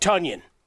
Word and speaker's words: Tonyan: Rodgers' arm and the deployment of new emerging weapons, as well as the return of Tonyan. Yes, it rhymes with Bunyan.